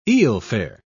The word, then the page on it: eelfare: British English